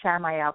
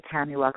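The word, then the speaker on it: Chamuel: Okay, Tamuel, some people pronounce it Shamuel, but it's C-H-A-M-U-E-L. I pronounce it Tamuel because I also work with another angel that's S-H, and that I call Shamuel.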